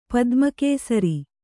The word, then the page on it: ಪದ್ಮಕೇಸರಿ: Kannada